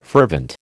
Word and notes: fervent: American English

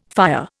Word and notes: fire: Indian

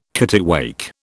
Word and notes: kittiwake: British English

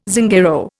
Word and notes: Zingaro: Indian

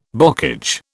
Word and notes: bocage: British English

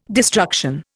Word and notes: destruction: Indian

